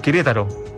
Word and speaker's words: Querétaro: El Querétaro, sí. ¿Ahí lo dije bien? Sí, de súper bien.